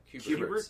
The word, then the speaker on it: Kubert: Is it the Kubert? – Kuberts. – Kuberts.